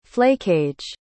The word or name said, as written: flakage